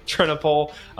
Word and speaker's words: Trennepohl: Trennepohl. So, okay. I overcomplicated that in my head, and I don't know why, so thank you. AJ Trennepohl, who goes to my church, and we got discussing some really cool things, and we're super excited to have him here.